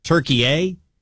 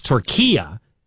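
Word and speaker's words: Türkiye: We've been pronouncing it wrong, and you're seeing people call it Turkey-A, but it's Turquia, he said, is the correct pronunciation.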